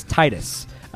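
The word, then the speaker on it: Tidus: But the boy with the blonde hair, he is the main character of Final Fantasy X, and his name is Titus.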